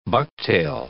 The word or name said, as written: bucktail